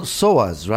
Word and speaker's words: Psoas: And you've written a book called The Vital Psoas, right? Is that how it's pronounced? – Correct. The Vital Psoas Muscle, yes.